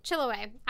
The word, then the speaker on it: Chiloé: This species got its name because Charles Darwin was the first scientist to describe it when he saw it on Chiloé. Did I just mess that up? You just told me how to say this. – Chiloé Island.